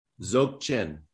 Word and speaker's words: Dzogchen: So today, you know, I wanted to talk about this whole new philosophy, what you're doing, Dogchen. And can you tell me how to pronounce it? – Dzogchen. – Dzogchen. Okay. Beautiful.